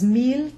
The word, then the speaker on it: mille: We talked a lot about the ILLE being the J glide or the yod, but we also mentioned the exceptions, mille, tranquille, ville, where the L is pronounced.